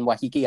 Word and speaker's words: Waiheke: He married his second wife, Fiona, in a secret ceremony in Waiheke Island. And I know that's the way it's pronounced because I was there.